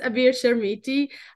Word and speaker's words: Abir Chermiti: So my name is Abir Chermiti.